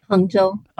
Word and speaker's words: Hangzhou: Am I saying that correctly? – Hangzhou, yes.